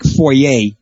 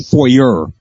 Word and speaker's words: foyer: It's just like foyer, not foyer, okay?